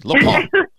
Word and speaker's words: Lupin: And I'm going, Le Pen. Did I say that correctly? Le Pen. – You said it even like a French person. You know how they say they get so like aggro sometimes when they say their words? It just like flies out. That was excellent.